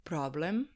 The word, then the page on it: problem: Serbian